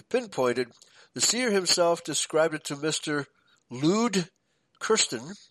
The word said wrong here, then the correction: Luet: Luet. Okay, it says two syllables.